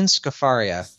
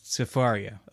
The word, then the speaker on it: Scafaria: So remind me, how do you pronounce her last name? The filmmaker? – Laureen Scafaria. – Scafaria. Okay.